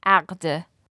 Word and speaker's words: aqd: That second word is a little difficult. It's aqd.